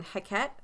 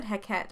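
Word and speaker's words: Heqet: So when you first said Heket, I thought you were talking about Hecate, but it's spelled differently. – It is. It's H E Q E T.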